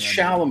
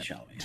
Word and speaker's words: Chalamet: It's Chalamet. Name. It's Timothy right. Chalamet.